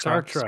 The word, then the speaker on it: Sartre: Is that how you pronounce that? Sartre? – Sartre. Sartre. Yeah, Sartre.